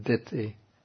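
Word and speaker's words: diṭṭhi: I don't know if I can say it correctly. Is it tanha, mana, and ditti? – Right. Tanha, mana, and ditti. That is craving, conceit, and wrong views or speculative views.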